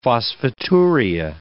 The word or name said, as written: phosphaturia